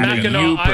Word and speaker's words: Mackinac: It's Mackinac.